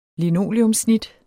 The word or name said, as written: linoleumssnit